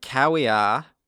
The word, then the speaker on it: caveat: I have told you that the V's are pronounced as double. – Okay. Caveat.